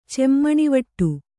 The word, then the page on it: ಚೆಮ್ಮಣಿವಟ್ಟು: cemmanivaṭṭu